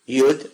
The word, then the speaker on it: yud: If you know how to read Hebrew, it's yud. U. Yud vav dalet. Yud. That's how it's pronounced.